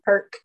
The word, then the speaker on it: Herc: And he goes by Cool, how do you say that last one? – Herc. – Cool Herc.